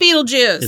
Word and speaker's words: Betelgeuse: It's pronounced Beetlejuice.